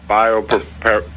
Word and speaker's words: Biopreparat: I mispronounce it. – Biopreparat, it's called. – Biopreparat.